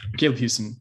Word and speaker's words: Caleb Houston: Caleb Houston, I said that right.